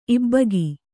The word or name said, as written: ಇಬ್ಬಗೆ